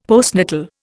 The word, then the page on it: postnatal: Indian